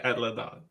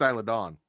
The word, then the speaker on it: Isla Dawn: she pronounced it Isla Dawn. – Yeah, you still can't pronounce debuted, but she fixed Isla Dawn.